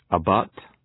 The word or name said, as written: abut